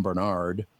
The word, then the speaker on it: Barnard: My name is Tom Bernard, but it's spelled B A R N A R D.